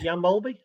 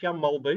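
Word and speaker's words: Jan Mølby: The, the ultimate example of this one is, is Jan Molby. It's not even pronounced like that. It's Jan Molbu. That's how you're supposed to pronounce it.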